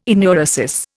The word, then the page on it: enuresis: Indian